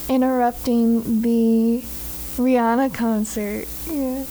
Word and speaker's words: Rihanna: We love Miss Rihanna as she, or Rihanna, sorry, excuse me.